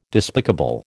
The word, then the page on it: despicable: American English